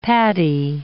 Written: padi

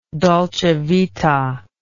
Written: dolce vita

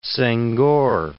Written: Senghor

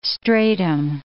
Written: stratum

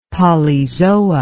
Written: polyzoa

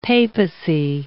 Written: papacy

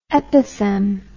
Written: epithem

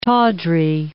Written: tawdry

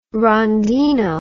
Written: rondino

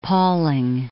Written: Pauling

